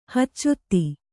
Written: ಹಚ್ಚೊತ್ತಿ

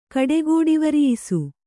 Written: ಕಡೆಗೋಡಿವರಿಯಿಸು

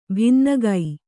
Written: ಭಿನ್ನಗೈ

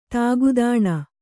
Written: ತಾಗುದಾಣ

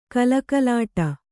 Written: ಕಲಕಲಾಟ

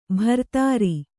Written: ಬಿರಾದರ

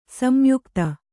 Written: ಸಂಯುಕ್ತ

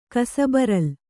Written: ಕಸಬರಲು

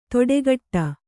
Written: ತೊಡೆಗಟ್ಟ

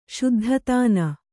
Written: ಶುದ್ಧತಾನ

